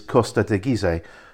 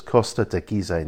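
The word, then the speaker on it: Costa Teguise: In Spanish, you always pronounce all of the syllables. So the E on the end becomes another syllable. So it's Costa de Gizeh, not Costa de Giz, as we would say in English.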